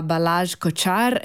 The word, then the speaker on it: Balázs Kocsár: Abbiamo sentito questa sinfonia eseguita dall'Ort, Orchestra della Toscana, diretta da Balázs Kocsár. Ringrazio Lia per avermi detto la pronuncia corretta di questo cognome,